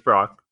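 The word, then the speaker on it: Brach's: So it's B-R-A-C-H-S, very common candy brand. And I looked up pronunciation. It is Brock.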